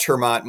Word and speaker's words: Termaat: Yeah, you can find us on Twitter at Termaat Mike. Of course, you'd have to spell it right? It's T-E-R-M-A-A-T.